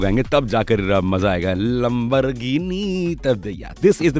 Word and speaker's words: Lamborghini: You know, best part about this song is that agar aap isko proper pronunciation ke saath gayenge Lamborghini to itna maza nahi aayega. You got to say Lamborghini theka hona chahiye.